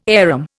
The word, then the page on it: arum: Indian